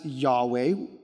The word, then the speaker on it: Yahweh: We pronounce it as Yahweh, right? We insert an A and an E there, but it could be any other vowels, right?